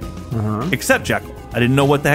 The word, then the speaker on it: Jekyll: Uh, in the Spencer Tracy movie, they pronounced it Jekyll.